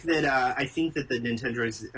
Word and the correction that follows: Nendoroid: how do you say it again? – Nendoroid. – Nendoroid, okay.